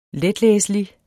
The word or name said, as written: letlæselig